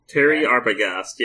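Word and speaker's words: Thierry Arbogast: Terry Arbogast? – Terry Arbogast, yes.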